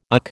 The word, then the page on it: UC: American English